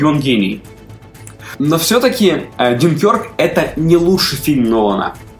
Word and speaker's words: Дюнкерк: В общем и целом, Дюнкерк классный. Он классный. И, кстати, я до просмотра называл его Дюнкерк. А оказывается, ударение все-таки на Е, да.